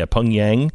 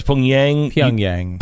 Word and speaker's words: Pyongyang: How do you say the Pyongyang? Yeah, Pyongyang. So it's Pyongyang.